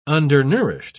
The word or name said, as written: undernourished